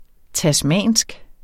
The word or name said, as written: tasmansk